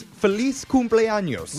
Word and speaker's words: Feliz Cumpleaños: It's F-E-L-I-Z. Well, all right? – Pronunciation matters. – Pronounce the Z.